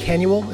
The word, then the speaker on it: Canuel: And Canuel is how you say your last name? – Yep.